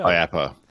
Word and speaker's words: IAAPA: IAPA is how they say it.